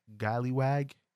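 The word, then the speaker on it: Golliwog: And there was a character that looked like blackface / I think it's pronounced Gallywag, which is like the character of the blackface with like the really big red lips and things like that.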